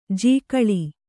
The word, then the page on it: ಜಿಗಳಿ: kannada